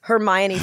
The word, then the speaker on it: Hermione: I did not know how to say Hermione until the movies came out. – Really? – I called her Hermione. – Hermione. A lot of people thought Hermione. – Oh, Hermione. Maybe.